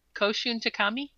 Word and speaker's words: Koshun Takami: 5 is Battle Royale by Koshun Takami. Did I say that right? – Yep.